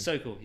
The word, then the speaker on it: Sokol: How do you pronounce the last name? – Sokol. You got it Sokol. – Right. Yeah, yeah.